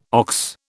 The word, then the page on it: ox: British English